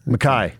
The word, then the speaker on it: Micah: It's not Micah, isn't it? – Mackay. – Mackay Bernard. Spelled Micah, but it's spelled Micah. It's Mackay.